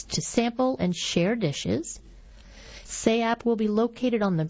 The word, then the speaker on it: SAAP: SAAP, spelled S-A-A-P, pronounced SAAP, which means delicious in Laotian.